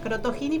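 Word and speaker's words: Crottogini: C-R-O-T-T-O-G-I-N-I. O sea, Crotogini, pero con doble T.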